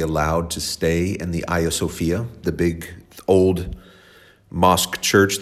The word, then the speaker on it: Hagia Sophia: It's spelled Hagia Sophia, but it's pronounced Hagia Sophia.